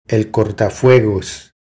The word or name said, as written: El cortafuegos